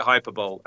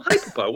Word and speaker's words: Hyperbole: We called it Hyperbole. And I bet many people still read that and see, oh, Hyperbole, what does that mean? Yeah, we'd mispronounced that name for years. Hyperbole.